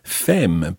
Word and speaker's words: femme: Now be careful with that one, because if you see that written down, you'll notice that it's spelled F-E-M-M-E. So you'd almost pronounce that, if you just looked at it, as femme, but it's femme.